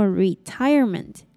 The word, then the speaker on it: retirement: 并且一样把重音放在第二个音节念作 retirement